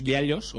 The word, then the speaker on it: giallo: Y estará, creo que él en persona, en la proyección de uno de sus giallos, o giallo, como se pronuncia.